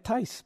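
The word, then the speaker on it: Theis: I don't know if it was spelled T-I-C-E. In America, I would get Tice, but it's spelled Theus, but it's called Tice.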